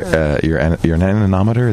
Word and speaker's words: anemometer: This is your anemometer.